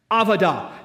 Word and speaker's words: avodah: Here's how it's pronounced. Avodah. Now, you say, I've never spoke Hebrew. Well, everybody's going to speak Hebrew today. All right, let's all stay together. Ready? Avodah. One more time. Avodah.